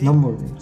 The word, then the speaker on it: Nürburgring: Es, creo, no sé si esté bien pronunciado, pero es Nürburgring alemán. – Ah, Nürburgring. – Sí, sí, sí. Nürburgring, sí.